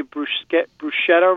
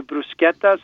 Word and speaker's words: bruschetta: P-O-R-C-H-E-T-T-A. That C-H is really important. Most of us say bruschetta wrong. It's actually bruschetta. So anytime you see a C-H in the Italian language, pronounce it like a K, because there is no K in the Italian language. So C-H replaces the K.